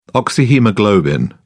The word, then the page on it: oxyhaemoglobin: British English